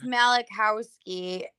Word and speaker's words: Malachowski: But it's Malachowski.